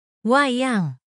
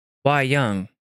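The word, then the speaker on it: wayang: Let's see how to pronounce it. – Wei Young. – Wei Young. – Wei Young. – Wei Young. Okay. So Wei Young.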